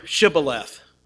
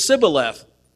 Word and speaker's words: Shibboleth: And in their accent, they didn't say Shibboleth, they said Sibboleth.